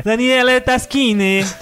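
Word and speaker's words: Daniele Tascini: Ele é um jogo do Daniele Taschini, que é o mesmo cara do Tzolk'in. – Falou errado, é. Daniele Taschini. Tem que fazer até a mãozinha, assim, pra falar italiano.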